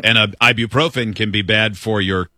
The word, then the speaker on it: ibuprofen: Ibuprofen.